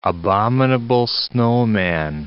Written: abominable snowman

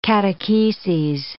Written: catecheses

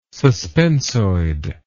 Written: suspensoid

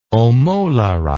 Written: omolara

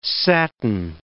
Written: satin

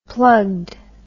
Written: plugged

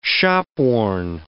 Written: shopworn

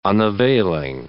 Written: unavailing

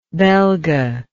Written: belga

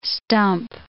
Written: stump

